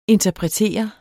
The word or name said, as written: INTERPRETER